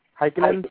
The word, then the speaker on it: Heikkinen: Ika Heikkinen, is that how you say his name? Heikkinen? Yeah.